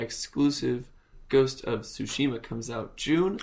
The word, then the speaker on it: Tsushima: We have more updates on Ghost of Tsushima.